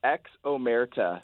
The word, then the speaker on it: Ex Omerta: Yeah, it's Ex Omerta.